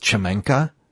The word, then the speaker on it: Szamanka: I'm talking about, it's pronounced Chamanka, S-Z-A-M-A-N-K-A.